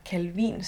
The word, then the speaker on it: Calvin: For eksempel den franske Jean Calvin, eller Calvin, som man gerne udtaler hans navn på dansk.